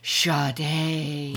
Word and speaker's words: Sade: It's spelled Sade, but she pronounces it Sade.